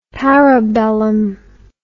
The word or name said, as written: parabellum